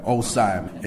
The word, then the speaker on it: OSIYM: It's uh, O S I Y M, and it's pronounced. So, like, when you pronounce it, think think cosine, but put the O in front, like osine.